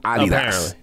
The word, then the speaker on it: Adidas: We say it wrong. Apparently, it's Adidas.